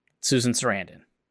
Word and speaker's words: Susan Sarandon: Susan Sarandon.